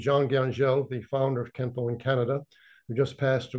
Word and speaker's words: Kenpo: So Kenpo, in Japanese, Xuan Fa is pronounced as Kenpo, which means law of the fist.